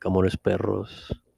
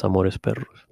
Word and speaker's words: Amores Perros: A lot of my favorite movies are turning 20 plus years, like Amores Perros. That's not how you say it. It's Amores Perros.